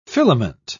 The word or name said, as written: filament